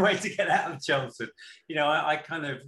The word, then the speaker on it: Chelmsford: Through some research, I found out that you went to high school in Chelmsford, just outside of London. I don't know if I'm pronouncing that right. Did you grow up there? – Chelmsford, yeah, absolutely.